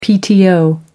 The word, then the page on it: pto: American English